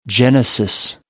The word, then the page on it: genesis: American English